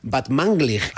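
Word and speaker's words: Batmanglij: Este es Rostam Van Banglig. – Muy bien. Casi bien pronunciado, ¿eh? O sea, tercero de Radio 3 tiene casi. – Van Banglig. – Batmanglig. – Batmanglig.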